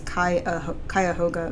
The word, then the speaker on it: Cuyahoga: It's actually spelled Cuyahoga, but I think the pronunciation is Cuyahoga Valley National Park.